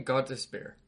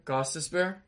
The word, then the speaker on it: Gostisbehere: Next at 8 is kind of a surprising name, but Shane Gostisbehere. – That was awful pronunciation. Shane Gostisbehere? – Gostisbehere? Yeah, that's probably better. Gostisbehere? – Yeah, that's probably better. – Yeah, definitely better.